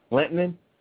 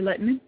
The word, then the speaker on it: Lehtinen: Lehtinen? Am I pronouncing that right, or is that yes. – The South Jersey pronunciation? – Kari Lehtinen.